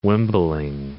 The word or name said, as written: wimbling